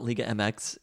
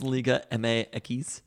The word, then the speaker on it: Liga MX: They actually call it not Liga MX, it's Liga MX. – Well, that's how X is pronounced in Spanish.